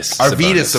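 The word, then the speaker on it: Arvydas: kind of similar to another Eastern European player, Arvin Sabonis. – Arvidas Sabonis. – Thank you. I had so much trouble pronouncing that.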